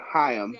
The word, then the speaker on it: Haim: So they actually pronounce her name Haim.